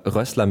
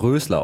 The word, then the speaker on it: Rössler: Es gab gerade technische Probleme und mir ist nämlich dann aufgefallen, dass du ja Rössler mit 2 S geschrieben wirst und ich habe dich aber Rösler ausgesprochen. Das ist ganz falsch.